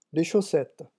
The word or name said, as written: les chaussettes